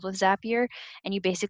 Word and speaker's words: Zapier: But uh, Zapier is a company that I followed for a few years. They're amazing. It's spelled Z A P I E R. Zapier rhymes with happier um, they are a software company and basically they are all about productivity for business owners and employees.